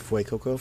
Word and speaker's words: Fuecoco: Then we're getting a croc for the fire Pokemon called Fucoco? – I think it's Fuecoco.